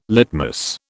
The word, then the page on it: litmus: British English